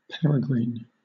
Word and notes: peregrine: British English

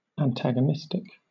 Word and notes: antagonistic: British English

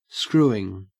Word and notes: screwing: Australian English

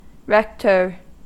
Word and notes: rector: American English

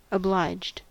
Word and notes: obliged: American English

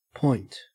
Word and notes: point: Australian English

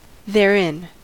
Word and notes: therein: American English

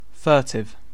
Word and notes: furtive: British English

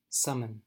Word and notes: summon: American English

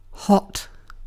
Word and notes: hot: British English

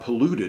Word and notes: polluted: American English

